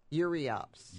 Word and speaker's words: Euryops: Is it too early to prune Europe daisies? – Euryops. – Euryops. Okay, she pronounced it a little bit different than I thought. It's spelled like Europe, right? – You can deadhead them.